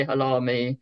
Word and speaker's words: Halahmy: And because he put the silent H in we say halami.